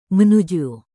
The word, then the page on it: menuju: Indonesian